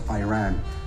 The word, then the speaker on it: Iran: Iranian people, but you're pronouncing our country's name wrong. Our country is named Iran, not Iran.